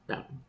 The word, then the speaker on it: Bowden: Bowden? How do you say it? – Bowden.